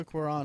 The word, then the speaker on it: Cuarón: it's Alfonso Cuarón and he's obviously, I don't know how to pronounce his last name, but that's right.